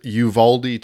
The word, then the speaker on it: Uvalde: This took place in Uvalde. I looked up how to pronounce it, Uvalde, Texas, a small town in southern Texas, actually near the Mexican border.